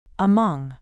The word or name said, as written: among